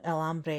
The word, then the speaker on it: hambre: It's harder to to enunciate properly, um, and you know el hambre is the same because the h is not pronounced.